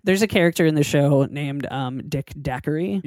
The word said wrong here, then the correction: Datchery: No, I just said it wrong. Dick Datchery.